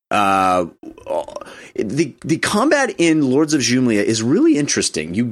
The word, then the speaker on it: Xumlia: called Lords of Jumlia. Jumlia, spelled with an X, Xumlia.